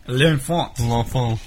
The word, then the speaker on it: L'Enfant: L'enfant.